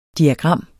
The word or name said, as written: DIAGRAMMERNES